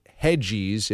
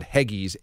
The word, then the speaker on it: Heggies: We also have, I believe it's pronounced Hedgies, if not Heggies, H-E-G-G-I-E-S pizza.